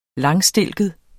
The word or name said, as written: langstilket